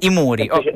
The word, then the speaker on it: IMURI: I Muri, com'è il nome? – I Muri.